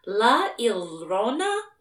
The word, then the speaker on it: La Llorona: Is it La Llorona or La Llorona? – It's, oh gosh, it's because it's Spanish, so the double L's turn into like a Y sound, so it's not the first one, but I know I'm not gonna be saying it super right.